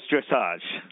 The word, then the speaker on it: dressage: Is it dressage or dressage? – It's dressage.